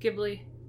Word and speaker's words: Ghibli: It's like GIF instead of Jeff. You know? How do you say it? – Ghibli.